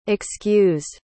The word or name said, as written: excused